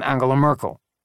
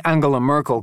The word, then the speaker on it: Angela Merkel: He practiced saying Angela Merkel correctly with a hard G, but in his version, the G was mocking and mincing.